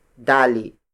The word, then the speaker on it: dali: The smallest unit of length in native Tagalog is dali. Dali. Dali. Dali is spelled as D-A-L-I.